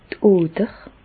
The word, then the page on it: d' Oder: Bas Rhin; Reichshoffen